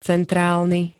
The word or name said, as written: centrálny